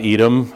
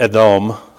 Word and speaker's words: Edom: Usually the emphasis is on the last syllable by default. Typically they are. That's kind of how they read. And so really, it would be Edom. We just say Edom, so I just say Edom, right?